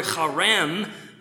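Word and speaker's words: Charem (חרם): The verb used there in verse 34 is a form of the Hebrew word charem. You know you're saying it right if you spit a little bit. Charem.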